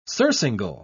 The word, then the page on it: surcingle: British English